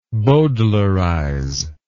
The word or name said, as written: bowdlerizer